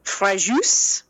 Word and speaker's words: Fréjus: The Malpasset dam broke in Fréjus. – Ooh. – Which is that I know I said right. I actually looked that one up. Fréjus.